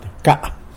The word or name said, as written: bca